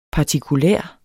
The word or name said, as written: partikulær